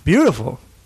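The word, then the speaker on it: Biutiful: Beautiful. Is that what it's called? Beautiful? – Yeah, it's not spelled that way. It's spelled like B-I-U-D-I.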